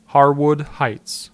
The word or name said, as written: Harwood Heights